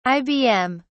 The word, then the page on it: ibm: British English